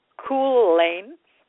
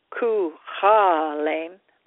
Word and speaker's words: Cuchulainn: It was broken in half sometime later by Cuchulainn, and that's C-U-C-H-U-L-L-A-I-N, Cuchulainn, that's how it would be pronounced, when it failed to proclaim him or his prodigy.